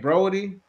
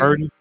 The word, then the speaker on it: Purdy: Brody or Birdie? How they pronounce his name? – Purdy. The quarterback.